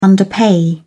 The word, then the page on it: underpay: British English